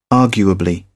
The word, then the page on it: arguably: British English